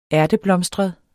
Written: ærteblomstret

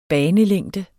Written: banelængde

